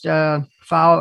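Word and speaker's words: Pfau: For our listeners, that's pronounced Fowle or spelled P as in Peter, F as in Frank, A-U.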